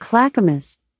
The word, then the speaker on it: Clackamas: Alexa, how do you say Clackamas? – I pronounce that Clackamas, but I'm always working on how to say things, and I might not have it right.